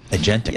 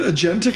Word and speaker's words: agentic: You're very agnetic. – Yeah, exactly. I'm getting there. Agentic. Yeah. All right. – And also, remember how you say it? Agentic? – Agentic AI. – Agentic.